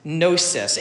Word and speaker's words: gnosis: Gnosis. It's spelled with a G though, so it's spelled like it's gnosis, but it's gnosis.